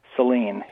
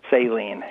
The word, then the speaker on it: Saline: Saline. Saline, yes.